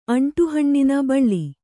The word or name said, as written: ಅಂಟುಹಣ್ಣಿನ ಬಳ್ಳಿ